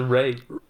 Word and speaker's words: Ralph: It's spelled Ralph, but he pronounces it Ray.